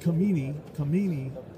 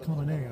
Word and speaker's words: Khamenei: It looks like Khamenei, but it's actually pronounced Khamenei, I found. – Khamenei, right.